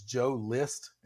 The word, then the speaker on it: Joe List: His name is Joe List. And it's just like a list of 10 things list. Oh, is how you'd spell it.